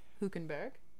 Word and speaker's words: Hülkenberg: And we cannot forget Hülkenberg. – Yes. – I look at Nancy because she's explained to me the proper pronunciation, and I hope I'm saying his last name. – Good. – Okay, good.